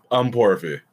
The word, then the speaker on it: Ukponrefe: Ukponrefe.